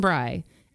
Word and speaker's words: Bry: And like, I have one of those simplest names, Kristen Bry. And people always spell my first name wrong. They spell it with an E. And people pronounce my last name wrong because they think it's Bray.